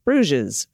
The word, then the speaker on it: Bruges: Bruges is pronounced with the oo sound and a soft G into a Z. Bruges.